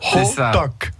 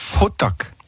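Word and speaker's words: hotteok: Hotok. On n'est pas loin. – C'est pas mal. – Deux syllabes. Hotok. – Voilà, exactement. – Dites-le, vous. – Hotok.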